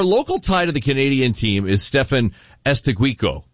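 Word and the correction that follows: Eustáquio: Is that how you pronounce it? – Estacchio. Yes.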